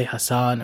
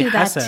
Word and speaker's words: Hassan: Also, something I'm working on, I think I talked about this before, is pronouncing my name the right way. – Mm-hmm. – So, you know, when it's a non-Arabic speaking person, I'll say Hassan or something.